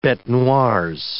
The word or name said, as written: betes noires